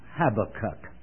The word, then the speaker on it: Habakkuk: Now, if I do what I said some other people do and slip now and then and pronounce it Habakkuk, you'll understand. But it has been impressed upon me that the emphasis is on the last syllable, Habakkuk.